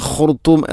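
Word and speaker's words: ഖുർത്തൂം (الخرطوم): 16-ാമത്തെ ആയത്തിൽ, സനസിമുഹു അലൽ ഖുർത്തൂം, ഇവിടെ സനസിമുഹു അലൽ ഖുർത്തൂം ഓതുമ്പോൾ സനസിമുഹു, ഹ എൻ്റെ ഉച്ചാരണം കൃത്യമല്ല, അതുപോലെ ഖുർത്തൂം എന്നതിലെ ഖ ത്വാഇന് പകരം ത. പ്രധാനമായിട്ടും രണ്ട് തെറ്റാണ് ഉണ്ടാവുക, ഒന്ന് ഖ മറ്റൊന്ന് ത. ഖുർത്തൂം, ഖുർത്തൂം എന്ന് തന്നെ ഉച്ചരിക്കണം. സനസിമുഹു അലൽ ഖുർത്തൂം.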